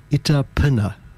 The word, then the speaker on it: Ittapnna: Go back to the Howard McGhee tracks here, and I'll play a track called Itapinna. Now, it's spelled I-T-T-A-P-N-N-A.